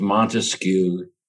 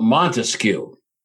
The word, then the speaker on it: Montesquieu: I don't know how much of a stickler I should be for the correct French pronunciation. Everybody in this country says Montesquieu, and I'm getting too old to kick against popular resistance too hard. So Montesquieu, Charles Louis Joseph Montesquieu.